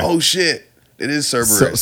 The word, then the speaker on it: Cerberus: It's not Cerebrus. It's Cerebus, the three-headed. – So, actually, I said it fucked up because it's Cerberus.